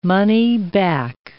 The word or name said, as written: money-back